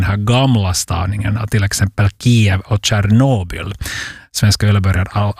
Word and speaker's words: Kyjiv: Bland annat huvudstaden som nu ska stavas K-Y-J-I-V, Kiev, uttalas det. Istället då för det här välbekanta Kiev.